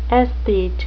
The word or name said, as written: aesthete